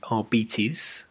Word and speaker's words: Arbites: So that's the Arbites.